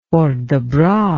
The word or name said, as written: port de bras